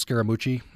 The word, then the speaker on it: Scaramucci: Hoochie coochie rhymes with Scaramucci.